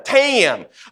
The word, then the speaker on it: Tim: It went from Tim to Tam.